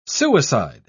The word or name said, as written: suicide